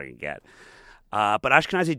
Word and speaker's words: Ashkenazi: I did the DNA test, and I found out that I'm 10% Ashkenazi. Am I pronouncing that correctly? – Yes, you are.